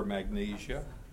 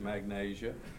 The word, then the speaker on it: Magnesia: That's at least the way I pronounce it. You may prefer Magnesia. I pronounce it Magnesia.